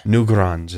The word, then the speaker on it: Newgrange: Newgrange is how you pronounce it.